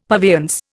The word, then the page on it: purveyance: Indian